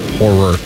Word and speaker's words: horror: What did I say? – Horror. – Two syllables. – Horror. – Thank you.